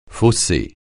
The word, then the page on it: fausser: francais